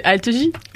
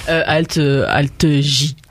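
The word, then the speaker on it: Alt-J: Non, c'est Algie. – Algie. – Bah oui, bah comment je le prononce ? – Bah non, mais c'est comme Armanet. – Ah oui, pardon. Alt J.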